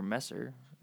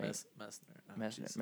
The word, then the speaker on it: Messner: So that kind of, I want to say, wraps up our episode on Reinhold Messner, or Messer, right? – Messner. – Messner.